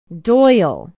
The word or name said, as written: doyle